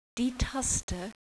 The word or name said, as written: taste